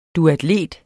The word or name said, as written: duatlet